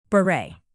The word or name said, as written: beret